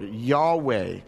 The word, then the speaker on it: Yahweh: We also know that he faithfully traveled every year to Shiloh to worship and sacrifice to the Lord, Yahweh. If you don't really fully aware what that word means, basically that's the how the Lord's name is pronounced in Hebrew commonly.